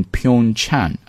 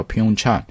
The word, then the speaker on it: Pyeongchang: will be held, 将在哪里举行，举行呢用的动词是 held, in Pyeongchang, 这个地方呢叫 Pyeongchang, 其实这个名字你就知道它跟平壤的距离不会远，因为平壤叫 Pyongyang, 这前面那个 Pyeong 都是一个发音，